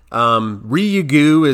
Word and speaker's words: Ryugu: And the asteroid's called Ryugu, but it's spelt R-Y-U-G-U. And Ryugu is maybe how you could say it, but in listening to the Japanese people speak it, they pronounce it Ryugu or Ryugu, so like Ryugu, just a little bit quickly there. So that's how I'm going to pronounce it, Ryugu.